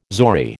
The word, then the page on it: zori: American English